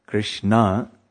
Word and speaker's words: Krishna: When there's a long A, the long vowel A, it's feminine. So Krishna is the masculine. That's the Supreme Personality of Godhead. But when you stretch the A, Krishna, that's the name for Yamuna.